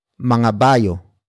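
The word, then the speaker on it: mangabayo: And that is mga bayo.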